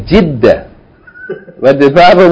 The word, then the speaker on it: جدة: Yeah, some people call it Jeddah. Some people call it Jeddah. But the proper pronunciation is Jeddah.